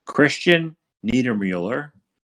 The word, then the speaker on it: Niedermueller: His name is, I'm gonna try to get this right, Christian Niedermueller.